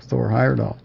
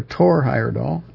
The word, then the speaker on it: Thor Heyerdahl: but it included the grandson of Thor Heyerdahl, or Tor Heyerdahl, as it would be pronounced in Scandinavia, or in the Germanic countries also.